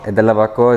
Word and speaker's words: De La Barbacoa: De la Barbacoa, is that how you say it? – Yes, sir.